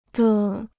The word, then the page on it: 的: de